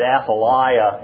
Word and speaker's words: Athaliah: Now, Chris pronounced it differently, and he pronounced it correctly according to the Hebrew, but I was raised to say it Athaliah, and I just can't reverse what my mother taught me, even if it wasn't quite so.